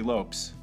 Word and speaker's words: Lopes: And rest in peace, Lisa Left Eye Lopez. And before that, you heard, or actually Lopes, like Davy Lopes. It's spelled the same way as the ball player, L-O-P-E-S.